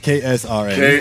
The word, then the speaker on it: KSRN: For people that don't know, man, the studio up there at KRSN is... did I say that right? Did I get a call? Did I get the call letters right? – KSRN. – KSRN. Okay, good.